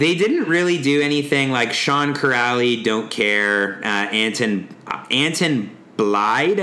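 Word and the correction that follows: Blidh: I think is how you say his name. – It's Anton Bleed.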